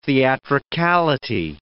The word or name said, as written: theatricality